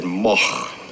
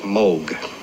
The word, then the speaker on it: Moog: It's originally a Dutch name, and in, in Holland you would pronounce it Moch. Now that's a little bit too much to expect people to do here. Uh, in Germany, uh, it's a fairly common name, and there it's pronounced Moog. In Great Britain, English speaking countries, uh, people see the double O and they say Moog. I just happen to like the sound of Moog better than Moog.